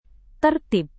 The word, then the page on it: tertib: indonesian